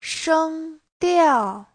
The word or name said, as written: sheng1diao4